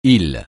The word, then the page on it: île: francais